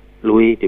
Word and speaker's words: Louis: Louis, Louis dibacanya, Louis ya, Louis seorang anak laki-laki berumur 9 tahun, sedang duduk dalam bengkel pembuatan pakaian kuda milik ayahnya.